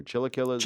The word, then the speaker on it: chilaquiles: I don't know if I'm pronouncing this chilaquiles or chilaquiles. – Chilaquiles.